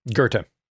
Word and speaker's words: Goethe: And Lewis, as a critic, when he's going through the depictions of spirits in literature, he goes through Dante, Milton, and is it pronounced Goethe? I've only ever seen it written. – Goethe.